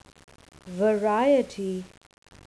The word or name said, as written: variety